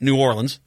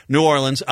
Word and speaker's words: New Orleans: Next hour, Terry John Zila is going to join us from New Orleans. I can't say it like that. New Orleans. There you go. Got to say it right. New Orleans.